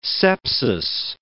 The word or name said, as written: sepsis